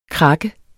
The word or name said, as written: krak